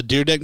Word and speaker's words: Dyrdek: First of all, Rob Dyrdek, is that how you say it? Dyrdek? – Dyrdek. – Right? – Yeah, Dyrdek.